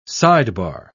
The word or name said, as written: sidebar